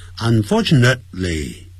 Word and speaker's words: unfortunately: 很不幸的呢叫 unfortunately, 啊那个 t, 不要念得很强，不要念成 unfortunately, 这样子 unfortunately, even today, 甚至在今天 in some areas, 啊不要念成阿利亚，我在上海听到有一个人念成阿利亚，听得蛮难过的。